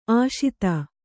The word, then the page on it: ಆಶಿತ: Kannada